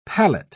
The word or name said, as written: palette